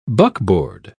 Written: buckboard

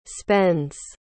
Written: spends

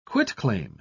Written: quitclaim

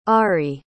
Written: arri

